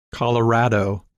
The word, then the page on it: Colorado: English